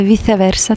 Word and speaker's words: Віце Верса: Так, це «Віче Верса», точніше «Віце Верса», так воно вимовляється, це латина, тому що я шанувальник Наталі Орейро з 13 років.